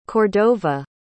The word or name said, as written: Cordova